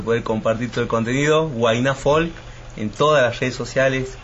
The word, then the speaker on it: Huayna Folk: Huayna Folk, WAYNA, acento en la A, y Folk como se escribe, FOLK. Así que para los que quieran seguirnos, abierto nuestras redes para toda la audiencia.